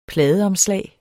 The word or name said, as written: PLADEOMSLAGET